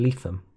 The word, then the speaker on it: Lethem: it was also quite educational for people that we've now told people it's actually Leatham.